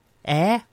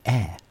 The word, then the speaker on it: Eyre: On the way, Ella Eyre. Air. How do you say E-Y-R-E? Air.